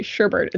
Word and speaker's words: sherbet: but they say sherbet in the song, and it is very catchy. And part of the explanation why people consistently mispronounce that is it is apparently a trait of the English language to make words sound like each other, so sherbet and herbert, which explains part of my difficulty saying shoot the sherbet herbert.